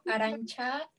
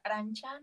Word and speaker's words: Aranza: Ok, bueno, yo me llamo Arancha. La pronunciación de mi nombre está chistoso porque se escribe como si fuera Aranza, pero se pronuncia Arancha, no sé.